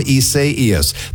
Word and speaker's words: Isaias: So let's talk about Tropical Storm Isaias. The only thing I really know is that's how you pronounce that name, in case you were wondering.